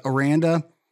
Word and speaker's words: Aranda: Seat talk is always like a little overblown, but I would say Dave Veranda. I'm always going to butcher the pronunciation or enunciation. Dave Aranda is much safer than Matt Campbell and Gundy, I would say.